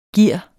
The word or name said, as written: gear